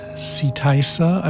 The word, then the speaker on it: Seetysa: Ahead of that, we heard Nick Tindall again, Seetysa, I believe is the pronunciation, S-E-E-T-Y-S-A, from the album Lagoon, released stateside on Hearts of Space Records around 1990 or thereabouts.